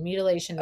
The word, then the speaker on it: mutilation: And then from that point, you go into the surgeries, which are the permanent mutilization and sterilization of, or mutilation, I said that wrong, mutilation and sterilization of the children's bodies.